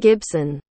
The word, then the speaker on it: Gibson: This time we're talking Neuromancer, the original cyberpunk sci-fi classic from William Gibbons. – William Gibson. – Did I pronounce it correctly? – Perfect, yeah.